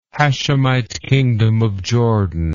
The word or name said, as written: Hashemite Kingdom of Jordan